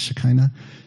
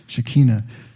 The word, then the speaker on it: Shekinah: For the Hebrews, it was Shekinah. Have you heard that word before? Sometimes pronounced Shekinah. Shekinah.